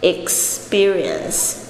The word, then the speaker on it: experience: Experience 这个 P 呢，在这个中间呢，我们不发 P 这个爆发音，我们发的是 B 这个声音。